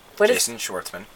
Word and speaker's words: Schwartzman: Jason Schwartzman. – Okay, that is his name. Jason Schwartzman all live together. Jason Schwartzman.